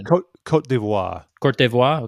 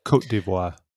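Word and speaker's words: Côte d'Ivoire: Cote d'Ivoire, okay. – Cote d'Ivoire.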